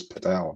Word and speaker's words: padel: I'll bow to their knowledge then and call it because it's spelled P-A-D-E-L, which is why I figured it was paddle.